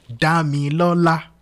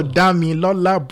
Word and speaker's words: Damilola: So for example, now if I were to coach you to say my name in Yoruba, it would be Oluwa Damilola or Damilola. – Damilola. – That's it. So Damilola, broken down in the Yoruba note, is mi re mi mi.